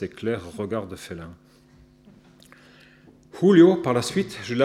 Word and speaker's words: Julio: Mais comme elle ne parvenait jamais à prononcer correctement la Jota, Julio se métamorphosait en Julio, avec un H puissamment soufflé du profond de la gorge,